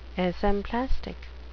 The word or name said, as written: esemplastic